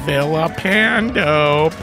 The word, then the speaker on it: Villapando: There's Vincent Villapando, pronounced with the L's, making an L sound, not a Y sound.